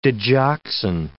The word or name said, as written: digoxin